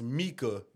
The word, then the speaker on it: Mika: He was like, nigga, that's not Mike. That's Mika. That's how they say it in German.